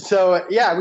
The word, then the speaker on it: Jira: I don't know if you've heard of it, but I also think I might be pronouncing it wrong. It might be Jira. – Yeah, it's Jira.